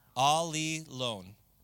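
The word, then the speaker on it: Ali lone (ἀλλήλων): It means alone, but it's you pronounce it with a li in the middle. So it's Ali lone.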